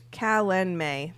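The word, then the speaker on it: Calanmai: Oh, look, pronounced Callan May. There we go. It spells it out for me.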